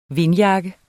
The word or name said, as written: vindjakke